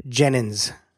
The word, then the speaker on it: Jenyns: The easiest way to access the show notes is to just go into Google and type in Productive Insights David Jenyns.